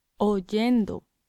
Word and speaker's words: oyendo: So we get oyendo. – Oyendo. And the spelling reflects this. This is, it's spelt O-Y-E-N-D-O, not O-I-E-N-D-O.